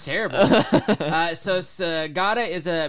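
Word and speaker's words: Sagada: How did I say we pronounce this? Sagata. Okay. – Yeah.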